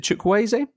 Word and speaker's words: Chukwueze: Is it Chukwueze? It's pronounced that? Yes.